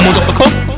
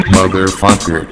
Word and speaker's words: Motherfucker: 그래서 후장이 아니라 족구릉에서 전이저지 기원하는 기분이야 모더퍼커. 영화하려면 발음 먼저 똑바로 해야지. – Motherfucker.